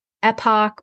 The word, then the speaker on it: epoch: And then in epoch, now this can be pronounced a few different ways. It's spelled E-P-O-C-H. So epoch or epoch is an iteration of training a model on the training data set.